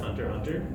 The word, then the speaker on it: Hunter x Hunter: Do you guys know like the background of the logo and the title, why it has an X if it's pronounced Hunter Hunter?